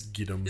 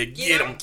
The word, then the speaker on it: Gidim: I think it's Giddim. – Just go Giddim! – Yeah, Giddim. Giddim. – Or is it G-I-D-I-M? – Yeah, they talk in alien language, and I'm pretty sure it's Giddim. – Cool. – It's G-I-D-I-M, and Bingo was his name, I think.